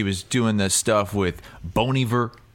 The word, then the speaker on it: Bon Iver: Bon Iver. – Bon Iver.